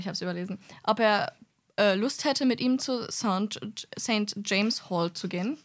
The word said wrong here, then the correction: St. James: St. James. You butchered that so hard.